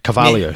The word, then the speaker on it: Carvalho: But he beat in a pretty great finals set against Marcio Carvalho. Carvalho, sorry. – You got to miss wrong syllable there, man. – Yeah, wrong emphasis.